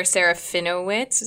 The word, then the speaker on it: Serafinowicz: I feel like Dylan Moran and Peter Serafinowicz, is that how you pronounce his name? – Yeah.